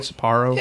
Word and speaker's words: Sapporo: Actually, this is the first time having it since the buyout, since they sold to Sapporo. Is that how you say it, Sapporo? – Yeah, that sounds good enough.